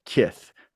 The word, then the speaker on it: KITH: Stephanie is an expert in crisis communication, and she is president of KITH. Did I say that right, Stephanie? – Absolutely. And it really does mean what you think it means, of kith and kin.